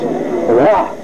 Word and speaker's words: Whuh: There's one stage which is spelled W-H-U-H, and of course is pronounced Wah,